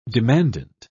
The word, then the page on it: demandant: British English